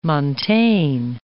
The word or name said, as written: montane